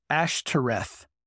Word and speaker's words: Ashtoreth: But in Hebrew, they deliberately misspelled that name to be pronounced Ashtoreth. And they use the vowels of the Hebrew word in there, which equate to shame, which I think is really interesting.